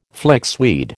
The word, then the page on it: flixweed: American English